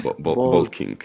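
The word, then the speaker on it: Boldking: Bolt con V, King como rey. – No, Bolt eh, con B-O-L-D. Ah, vale, Bolt. Perdón por, mi pronunciación.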